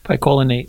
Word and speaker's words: picolinate: I mix it with chromium picolinate.